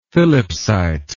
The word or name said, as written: phillipsite